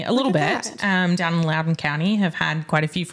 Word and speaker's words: Loudoun: It's spelled like it shouldn't be pronounced like that. – It does. It's an L-O-U-D-O-U-N. So it's the same. O-U. Yeah. It's Loudoun County.